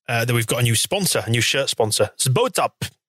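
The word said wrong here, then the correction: SBOTOP: SBO Top.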